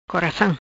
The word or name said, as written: Coracam